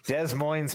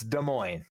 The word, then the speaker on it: Des Moines: but it's spelled Des Moines. Like, those people, – Des Moines people. Get it right. It's Des Moines. Like, they don't pronounce either S, and you're good.